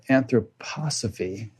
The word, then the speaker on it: anthroposophy: Susan is a longtime student of anthroposophy. Is that the right pronunciation, Susan? – Yep, that's great. Well done.